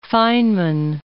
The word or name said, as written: Feynman